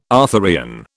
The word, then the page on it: Arthurian: British English